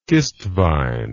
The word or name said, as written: kistvaen